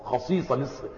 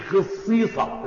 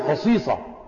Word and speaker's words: خصيصة: خصيصة مش خصيصة، لا كما ينطقها البعض هذا لحن، هي خصيصة.